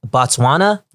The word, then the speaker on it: Botswana: Botswana? – Yes, sir.